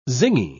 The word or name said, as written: zingy